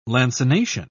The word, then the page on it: lancination: British English